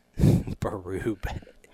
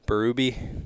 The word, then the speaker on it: Berube: Berube. Berube. – Berube.